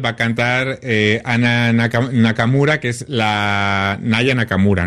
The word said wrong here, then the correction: Aya Nakamura: ¿no? Lo he dicho bien. Aya Nakamura, perdón.